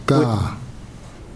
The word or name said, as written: kaa